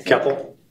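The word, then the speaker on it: Koeppel: Keppel. – How did she pronounce it? – Keppel. – With like an O-E-P-P? – Yes.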